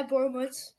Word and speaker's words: Borehamwood: Yeah, Borehamwood.